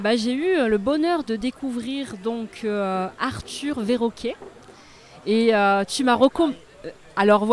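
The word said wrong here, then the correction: Verocai: ouais, mais je vais te laisser le prononcer. Ah vas-y, donc Arthur. – Arthur Verrocaille. – Verrocaille, voilà.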